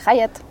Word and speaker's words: Hyatt: Y, por ejemplo, eh, después del, Reyes y todo, me fui al Hyatt, ¿se pronuncia? Sí, sí, el Hyatt.